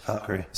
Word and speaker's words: Valkyrie: I really like the Valkyrie girl, which I think I say that wrong. But Tessa Thompson plays, right. What? The Valkyrie? – You said it right. – Yeah. – Valkyrie.